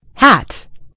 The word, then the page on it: hat: American English